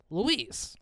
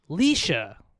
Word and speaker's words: Laoise: Her name is spelled L-A-O-I-S-E. So I just assumed it was Louise. – Oh, right? – Yeah. – Like, Louise is a regular name, okay? Well, it's actually pronounced Leisha. – No, it's not. Like Laos? Like L-A-O-I-S-E. – L-A-O-I-S-E, Louise.